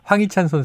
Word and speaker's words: 황희찬: 아까 황희찬 선수하고 이강인 선수 고르셨는데 제가 황희찬 선수를 황찬희라고 발음한 것 같아요.